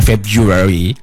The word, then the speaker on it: February: Itu bacanya mungkin untuk yang awal-awal February ya, bacanya February. Padahal yang benar itu February, February. Oh. Enggak pakai February itu enggak pakai biu, mentang-mentang ada u jadi biu itu enggak. Jadi bacanya itu February.